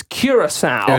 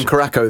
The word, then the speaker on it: Curaçao: That the players from Germany, and this is a terrible one to pronounce for me, but I think it's Curaçao. – It is. I called them Caraco the other day.